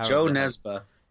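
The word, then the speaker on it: Jo Nesbø: Joe Nesbo is how they say it in the audiobook.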